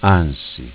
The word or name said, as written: Ansi